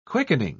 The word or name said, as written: quickening